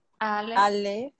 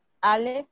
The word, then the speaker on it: Alef: Alef, Alef, Je G, Yud, Alef, G. – Ok, acuérdense que para pronunciar eh, las letras hebreas tienen que abrir más la boca.